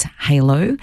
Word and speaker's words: Hailo: To the texter who has asked, the app is spelled H-A-I-L-O, that's Halo.